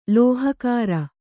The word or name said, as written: ಲೋಹಕಾರ